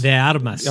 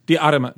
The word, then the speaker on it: De Armas: De Armas? – Mackenzie D. Armas. – De Armas. – Okay. De Armas. – De? Se ve. – De? – Armas. You got to roll that R, son.